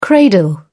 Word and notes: cradle: British English

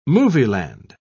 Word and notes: movieland: British English

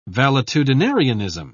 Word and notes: valetudinarianism: British English

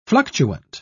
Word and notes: fluctuant: British English

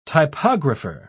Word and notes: typographer: British English

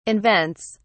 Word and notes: invents: British English

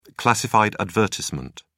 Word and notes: classified advertisement: British English

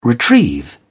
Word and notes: retrieve: British English

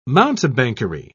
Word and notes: mountebankery: British English

